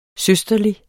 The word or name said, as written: søsterlig